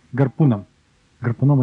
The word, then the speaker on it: гарпуном: 8 февраля уже в этом году был тоже успешно использован метод гарпуна, то есть выбросили щит, и потом гарпуном выстрелили, гарпуном это такое народное ударение, гарпуном выстрелили и захватили вот этот самый щит.